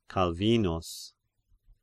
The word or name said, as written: Calvinus